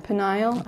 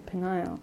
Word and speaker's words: Peniel: Peniel. – I can't say it right. – Just think of denial. – Oh, okay. Peniel.